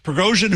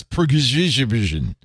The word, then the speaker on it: Prigozhin: Oh, I didn't know that's how you pronounce Prigozhin. You pronounce that Prigozhin? – Prigozhin. I put the extra syllable.